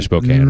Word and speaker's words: Spokane: It's actually Spokane, right? – Mm. – That's right. – I like how you pronounce that.